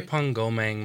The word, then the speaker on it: Drepung Gomang: Is it called the Diprang Monastery? – Drepung Gomang Monastery.